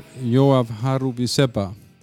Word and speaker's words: Yoav Haruvizepa: Okay, welcome to the bunker, Yoav Haruvizepa.